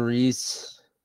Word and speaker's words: Rhys: John Reese. – Yeah. – It's like RHYS. It's spelled weird.